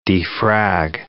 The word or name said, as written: defrag